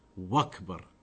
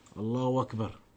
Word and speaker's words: Allahu Akbar: Allahu Akbar. People often they say it Allahu Akbar. When you're saying it, you're pronouncing a wow. There is no wow in that. It's Allahu Akbar.